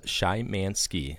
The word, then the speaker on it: Shymansky: Is it Shymansky? Like a shy man who likes to ski.